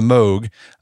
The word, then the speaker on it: Moog: Is that how you pronounce it? Moog or Moog? – I think it's Moog. – Moog.